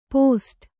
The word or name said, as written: ಪೋಸ್ಟು